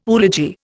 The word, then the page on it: oology: Indian